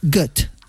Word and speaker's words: Goethe: Y según he visto, se pronunciaría Goethe.